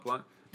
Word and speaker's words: Blount: you just can't get over that O in there, can you? – Blount. I used to call it Mark Blount was an old Celtics player. I used to. – Oh, really? – Yeah. So it's pronounced differently.